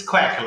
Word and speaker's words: Quek: It's Quek, like a duck sound, Quek.